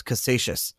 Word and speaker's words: Casascius: His name is Mike Caldwell, and he created the first physical Bitcoin, correctly pronounced as Casascius.